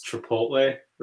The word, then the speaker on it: Chipotle: So like I pronounce Chipotle, right?